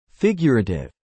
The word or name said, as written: figurative